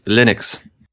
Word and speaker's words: Linux: I am Linus Torvaldis, and I pronounce Linux, Linux.